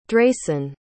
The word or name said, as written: dracen